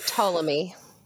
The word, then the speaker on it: Ptolemy: It's Ptolemy. – Ptolemy? – P-T-O-L-E-M-Y.